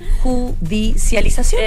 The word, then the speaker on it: judicialización: De ahí que se inicia toda esta judicialización. – No, lo dije mal, no puedo. A ver, judicialización. – Eso, perfecto, sí, perfecto. – Top.